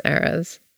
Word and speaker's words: Eras: Or is it Eras? – It's Eras. – Huh. That's how you pronounce that? – Mm-hmm.